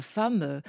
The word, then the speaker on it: femme: c'est que la femme, ça s'écrit pas avec... On entend un « a » et ça s'écrit pas avec un « a ».